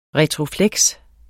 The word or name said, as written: retrofleks